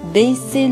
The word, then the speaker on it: baissez: Baissez le son, s'il vous plaît. Baissez le son, s'il vous plaît. 这里我要强调一下，一定要把它读清楚，不能把 baissez 读成 baissez，就是本来是两个 s 的，读成一个 s。